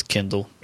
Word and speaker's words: Kindel: It's spelled K-I-N-D-E-L. – That'll change. Bezos will have that change. – Yeah. I figured that would do for me to pronounce it as Kindle.